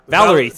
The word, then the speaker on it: Valerie: she played Valerina. The fuck, can't read. How do you say that? Valerie. Shit, damn. I said Valerina.